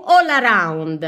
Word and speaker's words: All Around: Oggi siamo sul rap, la crew All Around, è scritto proprio come lo sto pronunciando, tutto attaccato, è un collettivo musicale romano, sono in 12, tra loro Casp, Biscotto, Meta, Giulia Tartaglia e tanti altri.